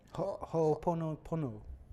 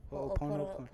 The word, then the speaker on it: Ho'oponopono: Ho'oponopono. Ho'oponopono.